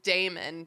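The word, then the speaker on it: daemon: I believe it's they're obviously spelled like they're pronounced Damon.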